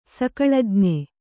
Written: ಸಕಳಜ್ಞೆ

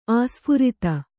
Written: ಆಸ್ಫುರಿತ

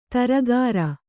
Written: ಧಾರಾಗ್ರ

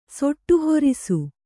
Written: ಸೊಟ್ಟುಹೊರಿಸು

